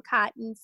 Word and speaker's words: Cotton: You spell it A-S-H-L-I, last name Cotton, C-O-T-T-O-N.